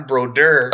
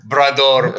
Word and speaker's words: Brodeur: Then it was weird because he pronounced Brador okay, but then when Martin Brodeur played for for the Devils, he would call Martin Brodeur Brador playing Brador.